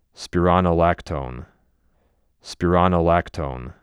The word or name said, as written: Spironolactone